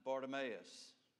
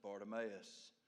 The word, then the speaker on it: Bartimaeus: Now, some of you have heard that pronounced other ways. This is the way I pronounce it. Okay? And if I tried to pronounce it any other way, I would say it four different ways tonight. So I'm going to stick with what I learned today as Bartimaeus, the son of Timaeus, sat by the road begging.